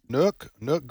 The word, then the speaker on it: Nuuk: According to the internet, it's Nuuk, and I'm sure I just mispronounced that. – Nuuk, Greenland. – N-U-U-K.